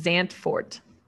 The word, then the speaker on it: Zandvoort: The official pronunciation is Zandvoort. So, Zandvoort. Yeah. Okay.